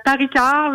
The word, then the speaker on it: Paris Art: J'espère que je le prononce comme il faut. Bonjour Mme Paré, comment allez-vous? – Bonjour Laurent, c'est Paris Art, la prononciation.